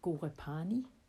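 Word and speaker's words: Ghorepani: Vi sidder ude i Nepal i 2800 meters højde i en lille by, der hedder Gorepani. Måske det er udtalt rigtigt, måske det er ikke udtalt rigtigt. – Jeg synes, det lyder rigtigt.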